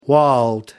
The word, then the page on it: walled: American English